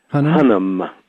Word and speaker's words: Hunnam: And how do you pronounce his last name, the actor's name? – Hunnam. – Charlie Hunnam, yeah.